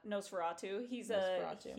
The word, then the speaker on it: Nosferatu: Nosferatu.